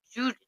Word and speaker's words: Giudice: Giudice. – That's how you say it? – Yeah, everybody's. – I've never known. – Everybody's been saying Giudice, but it's an Italian last name, so you, like, it's Giudice.